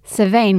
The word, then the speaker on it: Samhain: Samhain, which is spelled like nothing like Samhain. It's S-A-M-H-A-I-N.